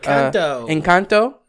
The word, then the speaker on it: Encanto: Encanto.